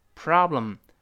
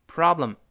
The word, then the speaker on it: problem: 当然，美式发音这个字母 o 发啊是 pro problem problem 这么一个声音。